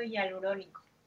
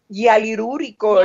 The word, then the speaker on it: hialurónico: Ácido hialurónico. – Hialurúrico.